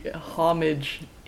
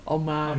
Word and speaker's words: homage: Yeah, if you look it up, homage is one of the correct pronunciations. And then if you want to go French, it's homage.